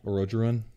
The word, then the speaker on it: Orodruin: Orodruin? – Yeah. – Yeah. – Okay. – Orodruin.